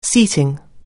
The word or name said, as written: seating